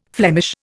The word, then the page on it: Flemish: Indian